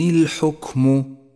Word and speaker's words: الحكم: المثال التالي على صفة الهمس، لاحظها لحرف الكاف في كلمة الحكم، إن الحكم، أجري النفس مع الكاف الساكنة لأنها حرف ضعيف يتقوى بصفة الهمس، أي هكذا إن الحكم إلا لله.